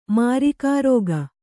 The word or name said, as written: ಮಾರಿಕಾರೋಗ